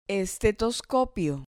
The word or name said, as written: estetoscopio